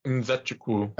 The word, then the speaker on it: Nzechukwu: They've been saying Nzechukwu. – Nzechukwu. – That's how John Anik has been saying it. – He would know. – I usually default to his pronunciation.